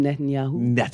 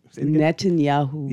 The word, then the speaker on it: Netanyahu: Benjamin Netanyahu. Netanyahu.